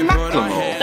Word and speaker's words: Macklemore: So did you say say Macklemore. – That's how you say his name.